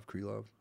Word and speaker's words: Krylov: How do you pronounce his name? Krylov? Krylov? – Krylov. – Krylov. Okay.